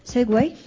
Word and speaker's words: Segway: También va a haber otro circuito de Segway, ¿lo he dicho bien? – Sí.